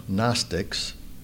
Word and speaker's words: Gnostics: Unfortunately, when John was writing, there were some people who were known as the Gnostics. That's the way to pronounce that term on the outline, Gnostic.